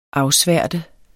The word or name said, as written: afsværte